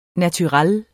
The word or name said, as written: naturel